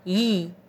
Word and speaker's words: Yi: The sacred mantra for the sixth energy chakra is Yi, spelled Y-I, and pronounced Yi.